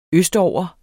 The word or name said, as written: østover